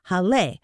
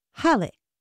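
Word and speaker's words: Hallé: But the accent is only there to let English speakers know that this was a German person's last name and that it was to be broken into two syllables and not pronounced Hall or something. But it is pronounced with the stress on the first syllable, Halle.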